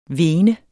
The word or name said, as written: vene